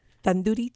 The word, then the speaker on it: tandoori: Tandoori chicken. – So I spent 5 minutes trying to pronounce what she just said. Would you like to try it? – Tandoori.